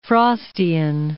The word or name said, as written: Frostian